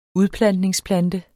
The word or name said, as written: udplantningsplante